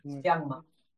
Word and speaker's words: Siangma: I don't know how to pronounce it in Inuktitut. – Siangma. Brown. – Siangma.